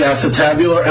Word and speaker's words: acetabular: When I look at the February 27th, 2009 press release, it's clear that there's some excitement about the R3 acetabular. Am I pronouncing that word correctly? Acetabular? – Yes, Your Honor.